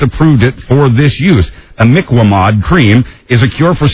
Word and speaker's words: Imiquimod: Imiquat. No, that's not right. Imiquimod. I finally got it.